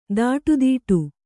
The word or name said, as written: ತೊಡೆದಟ್ಟು